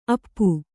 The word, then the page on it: ಅಪ್ಪೆ: appe